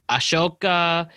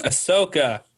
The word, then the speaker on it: Ahsoka: Ahsoka. We have the... – Ahsoka.